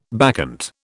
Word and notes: bacchant: British English

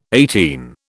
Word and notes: eighteen: British English

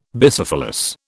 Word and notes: Bicephalous: British English

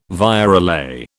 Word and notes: virelay: British English

